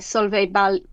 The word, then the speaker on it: Solveig Balle: The original series, I believe, was self-published in Danish by Solveig Balle, I think her name is pronounced.